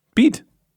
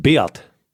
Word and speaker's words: Beat: Aber man spricht ihn, glaube ich, französisch aus. Wie spricht man den französisch aus? Weißt du nicht? Nein, Beat. – Das ist ein Schweizer und heißt Beat. Danke. – Beat.